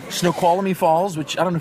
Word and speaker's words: Snoqualmie: Snoqualmie. That's the correct pronunciation.